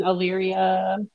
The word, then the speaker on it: Elyria: Again, if you're in Ohio, in the Wellington, is it Elyria? Am I saying that right? – Yeah, Wellington, Elyria, Lodi.